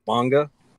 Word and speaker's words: manga: Did you call it a manja? – What? – Did you call it a manja? – That's how I pronounce it. Yeah, how would you pronounce it? – Manga.